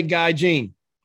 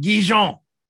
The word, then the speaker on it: Guy Jean: In America, we'd say Guy Jean, but en français, we pronounce it Guijon. So I'm going with Guijon.